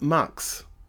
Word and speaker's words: Mx: Um, I'm not an X, um, but it's MX, and it's pronounced Mux.